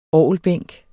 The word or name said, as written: orgelbænk